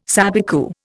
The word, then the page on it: sabicu: Indian